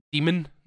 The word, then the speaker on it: Daemon: It's because Daemon is pronounced demon normally.